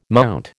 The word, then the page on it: MT: American English